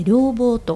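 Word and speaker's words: 両忘: そうですね、はい、先生、うん、今日はですね、あの禅の言葉で両棒、これ両方の両に忘れると書いて両棒と読むんですけれども、この両棒についてお話をしてくださるということなので、よろしくお願いします。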